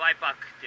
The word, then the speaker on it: Y-PAC: Y-PAC で、えー、 Y パックって読みま